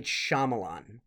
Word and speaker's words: Shyamalan: Apparently he officially pronounces it M. Night Shyamalan.